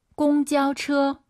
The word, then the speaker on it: 公交车: 공자오처. 일성이 연달아 세 개예요. 아아아 이 발음이 들려야 돼요. 한번 더. – 公交车. – 차 발음하실 때, 처 아니고, 처, 처, 처어라 생각하시고, 처, 매일.